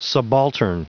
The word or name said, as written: subaltern